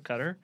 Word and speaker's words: Qatar: Qatar, I believe, is the generally accepted pronunciation. – Stick with Qatar.